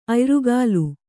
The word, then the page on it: ಅಯ್ರುಗಾಲು: Kannada